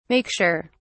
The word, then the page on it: make sure: British English